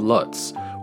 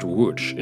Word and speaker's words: Łódź: It was named the European Tree of the Year for 2023, and it resides in the city of Lutz, which I learned is correctly pronounced Łódź in Polish, spelled L-O-D-Z with accents added to some of the letters.